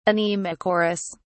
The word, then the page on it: anemochorous: British English